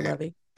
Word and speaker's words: Levy: So I don't know if that was, and I suspect Sean Levy, I can't remember how to pronounce it, I would say Levy.